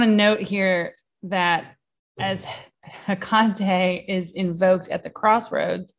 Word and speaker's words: Hecate: Hecate. – Yes. – Or Hecate, or however you want to say it. – Emphasis on the ka. – Hecate.